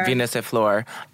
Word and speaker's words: Venus et Fleur: And, like, give her those $15,000 and, like, Venus and Flores. How do you say it? Venus and Flores? The flowers? – Is it Venus et Fleur? – Venus et Fleur.